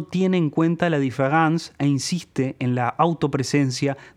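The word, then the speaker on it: différance: Aquí es donde entra en juego el neologismo concebido por Derrida, que lleva el nombre de différence, palabra que se pronuncia igual que différence en francés, que significa diferencia, pero se diferencia, valga la redundancia, en una letra.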